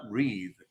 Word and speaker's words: Reade: Se llama Tara Reed. Se escribe Tara, así T-A-R-A, y su apellido es Reade, r e a d e.